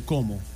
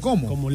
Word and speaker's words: Como: Bueno, hoy juega el Como contra el Genoa. El Como está en Champions League. Es importante verlo. El Como. – Así mismo, ¿cómo? – Como lago, es del lago Como en Italia. – ¿Y cómo se escribe? C-O-M-O, ¿cómo?